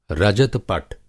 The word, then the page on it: रजत-पट: Hindi